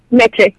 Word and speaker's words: Meche: Meche, yeah.